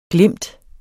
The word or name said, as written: glimt